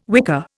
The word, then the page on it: wicker: Indian